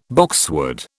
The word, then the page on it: boxwood: British English